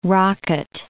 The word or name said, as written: rocket